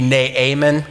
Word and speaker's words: Naaman: Now, Naaman, and actually, if you pronounce Naaman the correct way, it would be Naaman, Naaman like a goat, Naaman. But we aren't going to do it like that because it's much too complicated, so we're just going to say Naaman.